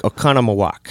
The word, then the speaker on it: Oconomowoc: How do you pronounce that? Okamoquak. – Oconomowoc. Oconomowoc. – Is it a reservation? – Oconomowoc, Wisconsin.